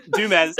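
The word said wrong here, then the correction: Du Mez: On, or Dumay. I said her name wrong, and it just totally failed. – Dumay, yeah.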